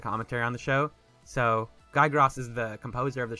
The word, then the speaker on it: Guy Gross: So it's not Guy Gross, it's Guy Gross. Gross. – Gross. – I don't know. It's spelled Grawss as a phonetic help.